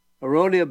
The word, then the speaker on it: aronia: Now, the berry, to me, that stands out as the super berry, it's called aronia berry. That's spelled A-R-O-N-I-A, aronia.